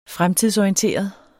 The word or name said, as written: fremtidsorienteret